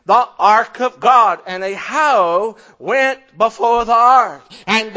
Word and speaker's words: Ahio: Sounds like Ohio, but it's Ahio.